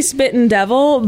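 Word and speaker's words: Spuyten Duyvil: Spite and Dival, I say Spit and Devil, but it's spelled quite creatively.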